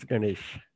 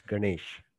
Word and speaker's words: Ganesh: How is that one pronounced? I don't want to butcher it. – It's Ganesh. You're right. It's Ganesh.